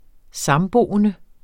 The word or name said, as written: samboende